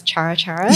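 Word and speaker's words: cara-cara: But also, coincidentally, like you would pronounce Chara Chara.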